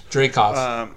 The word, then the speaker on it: Dreykov: Dreykov. – Dreykov, yeah.